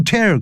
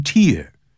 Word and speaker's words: tear: 他说呢 Their wear and tear 他们的磨损 Wear and tear 这个 tear 可不要念成 tear Tears 代表眼泪、啊、我们可以讲 Teardrops are rolling down her cheeks 也就是呢眼泪正沿着她的脸颊呢这样流下来 Teardrops是一个词 T-E-A-R-D-R-O-P